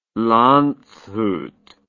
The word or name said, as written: Landshut